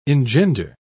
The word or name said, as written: engender